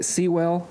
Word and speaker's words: Penei Sewell: Sewell.